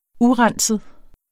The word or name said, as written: urenset